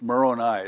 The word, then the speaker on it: Moroni: The gold angel up on the top, you know, a lot of people say Moroni, or, you know, something like, it's Moroni is the correct pronunciation, so now you know.